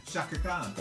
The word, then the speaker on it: Chaka Khan: Chaka Khan. – Okay, so it's not Chaka Khan.